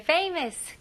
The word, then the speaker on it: famous: F 발음은 명확히 해주셔야 돼. 입술이 붙으면 안 되고요. famous. famous. 강세가 앞부분에 있습니다.